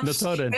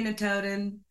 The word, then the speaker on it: Notodden: Notodden.